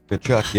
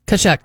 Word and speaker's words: Tkachuk: Tkachuk, yep. – Always, it's such a weird name. – The T is silent. – Tkachuk, okay.